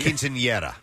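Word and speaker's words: quinceañera: It's the quinceanera. – Yeah. Your pronunciation is perfect.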